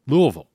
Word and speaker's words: Louisville: Don't pronounce the S. Louisville.